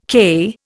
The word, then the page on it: KE: Indian